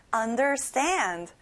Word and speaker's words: understand: 이해하다, understand. 친구들, stand에 강세를 넣어볼까요? Understand. Correct.